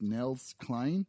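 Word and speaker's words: Nels Cline: I believe his name is pronounced Nels Klein.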